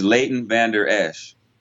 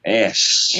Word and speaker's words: Leighton Vander Esch: His name is Leighton Vander Esch. – Esch. Okay. – Yeah. Esch.